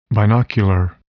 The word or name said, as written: binocular